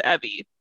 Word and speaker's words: Evie: We're gonna have to practice before we record, or we're gonna say it wrong the whole time because it is Evie. Evie Drake Starts Over. – Evie like Chevy.